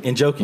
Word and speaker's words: Njoku: Njoku, you said that right.